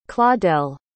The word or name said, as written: claudelle